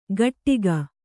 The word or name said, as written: ಘಟ್ಟಿಗ